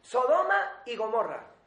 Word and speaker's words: Sodoma y Gomorra: Esta vez lo he dicho bien. Sodoma y Gomorra.